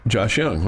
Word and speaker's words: Josh Jung: If there's too many people in Lubbock that call him Josh Jung. He was a star here. We should be able to get his name right. – Josh Young, right? – Yes.